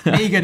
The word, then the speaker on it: M3GAN: Am I saying that right, guys? By the way, or is it should I go M3 Gan? I'm I'm not quite sure. – Megan is just fine.